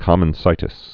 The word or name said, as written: common-site picketing